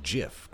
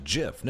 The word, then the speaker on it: GIF: They'd see that hard G, just as some people insist on seeing a hard G in GIF, graphical interchange format, which it's not, and I don't care what they say. I'm sorry, gosh, G, golly, grocery, that's a graphical interchange format, I'll pronounce it GIF. No, GIF is peanut butter. And I'm sorry, if you go back to the guy who invented GIF and say, actually, it's a J based on a fricative used by the Xhosa tribe in southern Africa, it's actually a GIF.